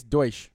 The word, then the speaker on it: Deutsch: But with some context, the VP for, I think it's pronounced, how do you pronounce that? – I think it's Deutsch.